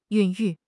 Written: 孕育

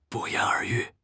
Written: 不言而喻

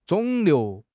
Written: 肿瘤

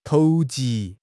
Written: 投机

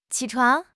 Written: qi3 chuang2

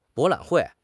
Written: 博览会

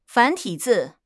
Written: 繁体字

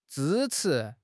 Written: zhi1 chi2

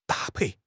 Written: da1 pei4

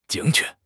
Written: 精确

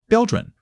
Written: 标准

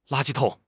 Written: la1 ji1 tong3

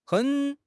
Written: hen3

